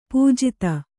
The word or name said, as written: ಪೂಜಿತೆ